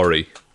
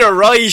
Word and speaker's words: awry: It would tend to go awry. – Ah, stop. I think you mean awry. I think that's how it's pronounced.